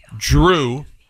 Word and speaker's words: Jrue: Tom, his first name is J-R-U-E. Drew. – Okay. – Is how you say that.